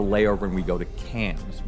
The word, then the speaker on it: Cairns: And and so the city you fly into out of Sydney is you go to Cairns, which is spelled C A I R N S. That's how it's pronounced. Wow. Yeah, it's pronounced Cairns.